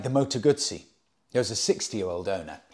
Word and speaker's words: Guzzi: And I explained to him, no, it's not, because two Z's in Italian is pronounced T-S, Guzzi.